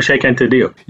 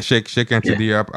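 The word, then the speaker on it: Cheikh Anta Diop: I also like that you had I've never heard his name out loud, so I don't know if I'm pronouncing it right. But Sheikh Diop. – Oh, Sheikh Anta Diop. – Yeah, Sheikh Anta Diop.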